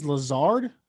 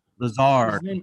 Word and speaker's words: Lazard: Is it Lazard? The receiver? – I think it's Lazard. – Lazard.